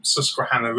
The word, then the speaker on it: Susquehanna: So these come from the Susquehanna River.